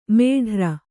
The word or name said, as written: ಮೇಢ್ರ